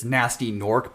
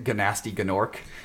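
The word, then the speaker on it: Gnasty Gnorc: I like that it's, you know, this nasty Nork, but people pronounce it Ganasty Ganork. – It's just so fun. It's just so fun that that people mispronounce it. Look, it's dumb English jokes, you know, saying that, like, hey, you know, the G in the N it makes an N sound, but we're not going to pronounce it that way. – No, you're not saying it right either. You got to say it like Spyro does.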